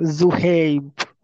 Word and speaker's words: Zohaib: No, Zohaib.